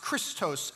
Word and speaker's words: Christos: And if you read into the New Testament, which was translated into Greek, you'll find that word Messiah isn't pronounced Mashiach as it wouldn't be in Hebrew. It's pronounced Christos.